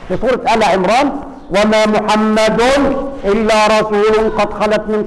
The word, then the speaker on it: محمد: احنا بنغلط في نطق اسم النبي، معظمنا بيغلط بيقول محمد، نطقها الصحيح محمد، وردت في القرآن 4 مرات محمد، لكن احنا بنقول عمك محمد. لا يا حبيبي، قول محمد.